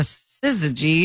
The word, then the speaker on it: syzygy: S-Y-Z-Y-G-Y. Syzygy? – Syzygy. – S-Y-Z-Y-G-Y? – Syzygy.